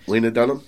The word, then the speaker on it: Lena Dunham: To make everyone else feel completely inadequate, Laura Dunning, it was like 23 or something. – Lena Dunham? – Lena.